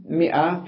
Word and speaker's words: مئة: إذن مئة في الإفراد وفي التثنية تكتب الألف ولا تلفظ، مئة ومئتان، حتى في الجمع ومركبة، ليس في الجمع، قال مركبة مع الآحاد.